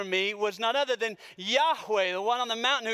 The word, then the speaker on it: Yahweh: YHWH would have likely been, uh, been pronounced Yahweh.